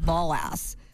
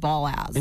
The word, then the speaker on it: Balazs: That's not how you pronounce it, by the way. It's called Balazs. – Balazs. – Balazs. – That sounds like a terrible joke waiting to happen. – No. That's really how you pronounce it. – Really? – Hungarian. Balazs.